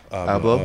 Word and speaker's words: Abloh: Abloh.